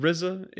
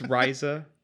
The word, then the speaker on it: Ryza: Ryza, if you're from the Wu-Tang pronunciation. If not, it's Ryza.